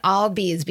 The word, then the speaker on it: Albies: Ozzie Albies, and I wrote down Albies because that's how you taught me to pronounce his name.